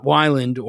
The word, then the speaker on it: Weiland: And Scott Weiland, or Whelan, depending upon how you want to pronounce it, to your point, man, that guy moved around the stage like a snake, like a lizard.